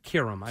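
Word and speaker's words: Qerim: And it's Stephen A., and it's Max, and it's Molly Quirum. Is that how you say it? – Kiram. – I apologize.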